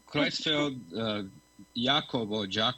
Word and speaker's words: Creutzfeldt-Jakob: É essa mesma a pronúncia para a gente? – É, Creutzfeldt-Jakob, Jakob. Tanto faz.